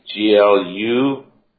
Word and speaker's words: Glühwein: Gluwine. The W in Swiss German is pronounced like V. – Right. – It's Gluwine.